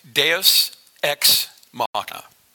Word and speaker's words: Deus Ex Machina: And it's pronounced Deus Ex Machina. And Deus Ex Machina is a Latin phrase, and it was derived from, it literally means, if you want to go to the next slide there, yeah, oh, thanks, Tyson.